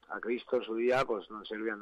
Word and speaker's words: non serviam: que realmente es una errata del latín, porque bien pronunciado sería y bien escrito es non serviam, es con A.